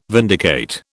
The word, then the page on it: vindicate: British English